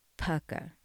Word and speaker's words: Pukka: And the tea of choice this evening is by Pukka. I think I've mispronounced this a few times now, but it's P-U-K-K-A. I did look it up, and I do think it's called Pukka.